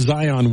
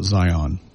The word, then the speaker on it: Zion: It's spelled Z-I-O-N. – Really? – Yeah. But all the locals back there called it Zion.